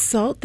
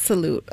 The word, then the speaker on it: Sault: It's salt, that's how you say it? – S-A-U-L-T. – Yeah, I thought it was salute. Okay. Well, did you? – No, I don't know. – It kind of does look like salute. No, it's salt. S-A-U-L-T.